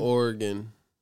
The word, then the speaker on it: Oregon: It's Oregon. – I need to know the truth. It's called, it's spelled O-R-E-G-O-N. – Yeah. But it's pronounced Oregon. Like Oregon. Like Oregon.